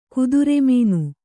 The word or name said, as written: ಕುದುರೆಮೀನು